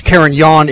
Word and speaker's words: Carignan: Although it's pronounced Carignan, it does not produce the sense of a yawn.